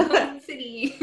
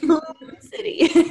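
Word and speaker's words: Hewn City: Wait, how do you say it? – Hoon? The Hoon City. – Hoon City.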